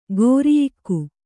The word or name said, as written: ಗೋರಿಯಿಕ್ಕು